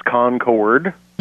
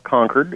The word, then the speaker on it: Concord: It's Concord. – Mm-hmm. – It's not Concord. It's not the grape. It's the plane.